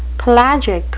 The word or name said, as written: pelagic